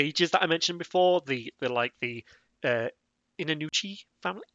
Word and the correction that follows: Jinnouchi: Did I get the name right? Is it Inanuchi? – Uh, Jinnouchi, yeah.